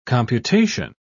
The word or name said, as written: computation